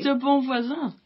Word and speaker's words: de Bonvoisin: Is it how you say it, really? – De Bonvoisin. – De Bonvoisin. So the S is a Z.